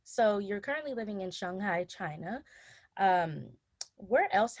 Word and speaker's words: Shanghai: I hope I'm saying it right. Shanghai, right? – Yeah, Shanghai. – Shanghai. Okay. All right, good.